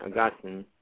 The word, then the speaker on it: Augustine: And the second one, Confessions of St. Augustine, Augustine is probably the correct, although many Americans say Augustine, like, you know, St. Augustine, Florida.